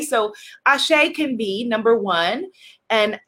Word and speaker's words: Ase: Ashe, let's affirm it. Type into the comments, Ashe. A-S-E is the easiest way to spell it.